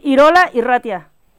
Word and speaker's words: Irola Irratia: ¿así se pronuncia? – Sí, Irola Irratia, sí, que quiere decir, está en euskera, que estamos en País Vasco, y aquí hablan el euskera.